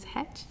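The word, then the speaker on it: Hecht: You were elected by the, for the Texas Judicial Council, mm-hmm, by Chief Justice Hetch. Did, mm-hmm, I pronounce that right? – That's correct.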